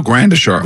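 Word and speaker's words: Gradishar: Is that how I say his name? Gardashar? – No, Grandashar.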